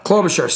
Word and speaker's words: Klobuchar: Klobuchar.